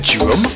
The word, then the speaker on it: Djrum: London's, I think you pronounce it Drum, D-J-R-U-M.